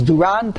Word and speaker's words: Duran: Duran, the way it's pronounced in English is Duran.